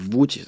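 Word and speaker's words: Łódź: et mäletan Poolas meil Lutšis, õigemini Vutšis tähendab, hääldatakse, oli äh, tohutu suur klubi, suurem kui Rock Cafe mm.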